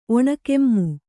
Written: ಒಣಕೆಮ್ಮು

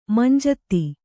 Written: ಮಂಜೂತಿ

